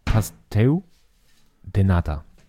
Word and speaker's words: Pastel de Nata: Pastel, aber ich glaube, das L hat im Portugiesischen eher so ein U. Die sagen auch nicht Portugal, sondern Portugal. natürlich viel portugiesischer ausgesprochen, deswegen würde ich sagen, Pasteu de Nata.